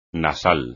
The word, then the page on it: nasal: Spanish